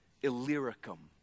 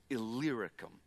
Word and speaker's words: Illyricum: Illyricum. That's how you say it. Illyricum.